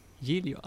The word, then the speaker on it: Giglio: Costa Concordia gick på grund där utanför med reservation för uttalet här. Ja. Giglio. – Giglio, ja, det låter bra ändå.